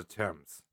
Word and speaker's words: Thames: Is that how you pronounce Thames? – It's the Thames. – Thames? – Yeah. – Bastard. – It's all right. It's weird. It's spelled stupidly.